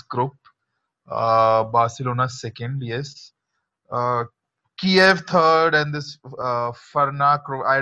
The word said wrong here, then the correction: Ferencvárosi: It's pronounced Ferencvárosi.